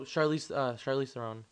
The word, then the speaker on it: Charlize Theron: Oh, Charlize Theron.